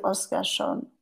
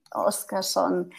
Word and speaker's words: Oskarsson: And um, then I have a website that is uh, Ivy Oskarsson, Oskarsson uh, dot podia dot com.